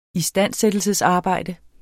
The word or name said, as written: istandsættelsesarbejde